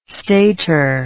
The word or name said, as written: stater